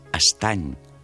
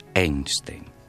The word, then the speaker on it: Einstein: Acabo de saber que el seu, triat per ell, en homenatge a la ciència i mal pronunciat pels seus homes, no era pas Estany, sinó Einstein. De Gaulle li havia posat personalment l'any 44 la més gloriosa de les creus de guerra.